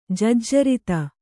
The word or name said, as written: ಜಜ್ಜರಿತ